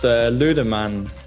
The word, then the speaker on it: Lüdemann: Almost, Lüdemann.